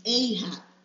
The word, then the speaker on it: Ahab: I have to make sure I pronounce it right, because it's Ahab.